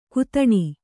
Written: ಘಾತಿನಿ

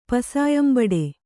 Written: ಪಸಾಯಂಬಡೆ

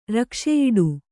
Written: ರಕ್ಷೆಯಿಡು

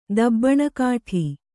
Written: ದಬ್ಬಣಕಾಠಿ

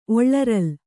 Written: ಒಳ್ಳರಲ್

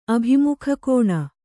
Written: ಅಭಿಮುಖಕೋನ

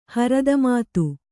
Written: ಹರದಮಾತು

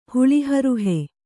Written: ಹುಳಿಹರುಹೆ

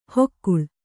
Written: ಹೊಕ್ಕುಳ

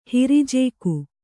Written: ಹಿರಿಜೇಕು

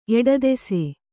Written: ಎಡದೆಸೆ

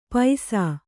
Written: ಪೈಸಾ

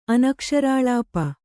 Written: ಅನಕ್ಷರಾಳಾಪ

